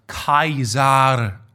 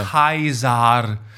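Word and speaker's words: Caesar: Und unser Lateinlehrer meinte mal, dass Cäsar eigentlich Kaisar ausgesprochen wurde. Das war alles. Das war der Laut, das war dieses Singelsangel. – Ja. – Kaisar.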